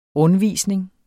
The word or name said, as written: rundvisning